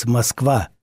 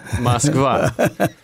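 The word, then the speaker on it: Moskva (Москва): In Russia, it's Moskva. – Moskva.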